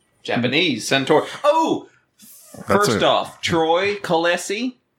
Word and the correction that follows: Clohesi: I think I said his name right. – Clohesi.